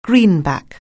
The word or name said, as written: greenback